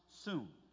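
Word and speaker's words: soon (σύν): And in the transliterated Greek, it would be S-Y-N. But it's pronounced soon.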